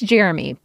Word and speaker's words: Jeramey: I know it's Jeremy, but his mom decided to spell it J-E-R-A-M-E-Y. Jeremy.